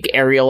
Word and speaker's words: Ariel: Ariel, I'd probably say Ariel like Little Mermaid, but it's Ariel.